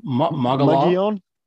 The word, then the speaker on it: Mogollon: Since we're talking about cold climates, I understand you had a bit of a cold night on the, how do you pronounce that rim? Mogollon rim.